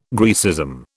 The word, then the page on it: Graecism: British English